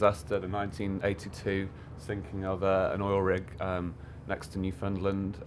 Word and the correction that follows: Newfoundland: It's spelled Newfoundland, but it rhymes with understand. In Canada. – Newfoundland.